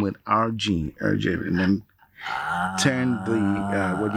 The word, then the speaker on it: Hergé: Hergé is essentially RG, pronounced like French. Hergé.